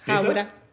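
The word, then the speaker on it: Hāwera: Okay, so if I said Hawera? – Yes, Hawera. Very good. – Can you say it? – Te Hawera.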